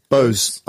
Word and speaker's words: Bose: I'm never sure how to say this brand name. Bose, is it, or Bosa? – Bose. I would say Bose, the brand that makes speakers.